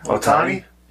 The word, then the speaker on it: Ohtani: And, obviously, it's all because of, how do you say his name? – Ohtani? – Yeah.